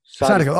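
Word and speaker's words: Sadykhov: So because of the KH, it makes a H sound. So it's Sadikov.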